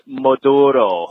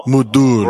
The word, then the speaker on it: Maduro: It is pronounced Maduro. – Maduro. – Brown ale. Oh. You got to say it with a little bit of a Spanish Maduro.